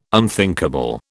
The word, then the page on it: unthinkable: British English